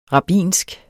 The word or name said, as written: rabbinsk